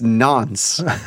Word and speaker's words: nonce: Nouns? It's nonce. – How do you pronounce? – N-O-N-O.